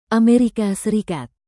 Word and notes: Amerika Serikat: indonesian

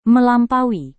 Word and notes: melampaui: Indonesian

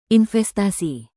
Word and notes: investasi: indonesian

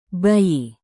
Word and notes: bayi: indonesian

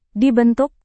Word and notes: dibentuk: indonesian

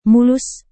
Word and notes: mulus: Indonesian

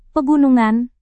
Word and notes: pegunungan: Indonesian

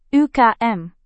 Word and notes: ukm: indonesian

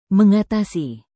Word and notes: mengatasi: Indonesian

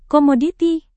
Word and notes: komoditi: indonesian

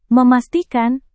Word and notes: memastikan: Indonesian

